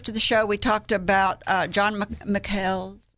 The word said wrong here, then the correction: Michel: McHale? – Michel. – Don, John Michel's. that's how you say his name because he's French.